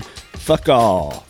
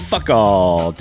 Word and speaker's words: Foucault: In 1851, French physicist Léon Foucault demonstrated fuck all. I think that's how that's pronounced. – That's how I feel today. – Take another crack at that. In 1851, French physicist Léon Foucault demonstrated how our planet rotates using his now famous pendulum.